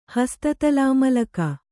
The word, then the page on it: ಹಸ್ತತಲಾಮಲಕ: Kannada